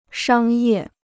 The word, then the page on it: 商业: shāng yè